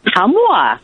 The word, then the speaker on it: Ottumwa: And in Ottumwa, Iowa. Am I saying that right? – Ottumwa, Iowa.